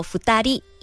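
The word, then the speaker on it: ふたり: 意思就是一个人，而妹妹叫二里，念作福达利，意思就是两个人。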